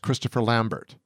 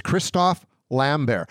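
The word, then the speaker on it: Christophe Lambert: It's actually not pronounced Christopher Lambert. It's Christoph Lambert.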